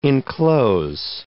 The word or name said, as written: enclose